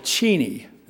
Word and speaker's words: Cheney: I amaze me that people like John Bolton and Wolfowitz and W and those people and Cheney, and that's how you pronounce his name, still wander about the way they do.